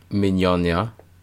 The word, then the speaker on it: Mignogna: Mignogna.